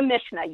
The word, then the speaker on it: Mishnah: um, Mishnah. Did I say that correctly? – The Mishnah, yes.